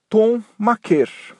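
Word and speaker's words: Thon Maker: Então o certo é Tom Maker. Mas essa altura o assunto já é praticamente um caso perdido, porque mesmo lá nos Estados Unidos, todo mundo fala maker, com essa pronúncia americanizada.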